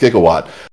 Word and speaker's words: gigawatt: They had to invent ideas, but they used words that were real words, real scientific terms, like a gigawatt, even though they mispronounced it. It's pronounced gigawatt.